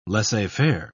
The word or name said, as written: laissez-faire